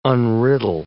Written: unriddle

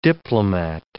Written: diplomat

